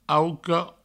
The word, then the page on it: auca: auco